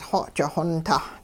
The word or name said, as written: xoji-xontah